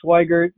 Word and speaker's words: Swigart: My name is Rudy Swigert.